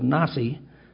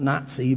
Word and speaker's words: Nasi: Hillel II, also known simply as Hillel, held the office of Nasi, I guess I pronounced that way, of the, not Nazi, but N-A-S-I, of the ancient Jewish Sanhedrin between 320 and 385 Christian era.